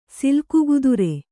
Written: ಸಿಲ್ಕುಗುದುರೆ

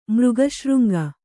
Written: ಮೃಗಶೃಂಗ

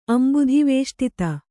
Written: ಅಂಬುಧಿವೇಷ್ಟಿತ

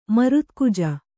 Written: ಮರುತ್ಕುಜ

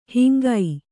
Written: ಹಾಂಗೆ

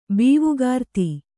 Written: ಬೀವುಗಾರ್ತಿ

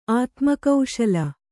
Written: ಆತ್ಮಕೌಶಲ

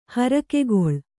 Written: ಹರಕೆಗೊಳ್

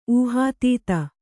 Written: ಊಹಾತೀತ

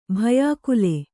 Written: ಭಯಾಕುಲೆ

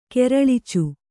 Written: ಕೆರಳ್ಚು

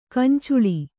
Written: ಗೊಂಚಲ್

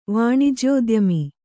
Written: ವಾಣಿಜ್ಯೋದ್ಯಮಿ

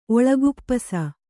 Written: ಒಳಕುಪ್ಪುಸ

